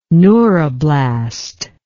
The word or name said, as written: neuroblast